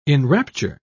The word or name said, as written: enrapture